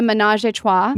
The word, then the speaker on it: ménage à trois: The ménage à trois.